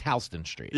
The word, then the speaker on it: Houston Street: Like, I mean, in New York, Houston Street is pronounced Halston Street. – Yeah. I'm like, wait, where? – And as a cab driver, you're looking for H-A-L-S-T-O-N for about 3 weeks.